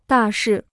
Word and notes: 大事: dà shì

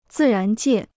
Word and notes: 自然界: zì rán jiè

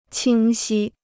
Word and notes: 清晰: qīng xī

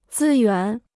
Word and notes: 资源: zī yuán

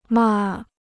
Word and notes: 骂: mà

